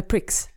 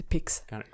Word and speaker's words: Prix: vilket är Star Fox ehm, med titeln Star Fox Grand Prix Äh, Prix, inte Pix. Grand, Grand Prix? Grand, ja, ah, Prix. Ja, ah, okej, okay. jag kan inte läsa ordentligt. – Ja, eh, det, det, står ju Prix, men det uttalas Grand Prix.